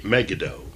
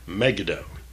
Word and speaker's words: Megiddo: And the servants, we said Megiddo, we often mix this up with the Battle of Armageddon, but it's really Megiddo. The emphasis here should be on the very last syllable, as it is in most Hebrew names and words, Megiddo.